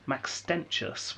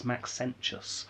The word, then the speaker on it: Maxentius: Not Maxentius, which I mispronounced last week. It's Maxentius.